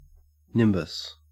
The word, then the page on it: nimbus: Australian English